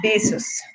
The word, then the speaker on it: Bezos: ¿Cómo se dice Jeff Bezos? O sea, ¿si es Bezos o Bezos? – Pues según yo es besos, besos.